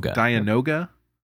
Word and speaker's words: Dianoga: Dianoga.